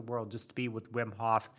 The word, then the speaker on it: Wim Hof: So then later, when you would read about people like Wim Hof, the Dutch Iceman, you know, the guy who like swims underneath sheets of ice and does breath work and cryotherapy.